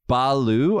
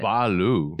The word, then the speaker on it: balut: Okay, how about in the Philippines, they have balu, I think it's called. – What is it? – Balu. – It is spelled B-A-L-U-T.